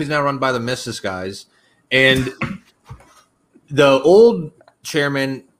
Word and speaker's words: Mises: But so LP Kentucky is now ran by Mises guys, though. So Mises, it's pronounced Mises.